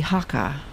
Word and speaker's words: Hakka: you spoke a lot about the Hakka as well.